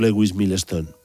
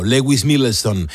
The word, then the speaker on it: Lewis Milestone: Por cierto, el guionista Horton Foote ya había colaborado previamente con Steinbeck en la película de 1949, El Pony Rojo, dirigida por Lewis Milestone. – Muy bien pronunciado, Lewis Milestone.